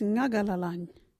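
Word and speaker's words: Ngagalalany: Our local Wadjuri word is Ngagalalang.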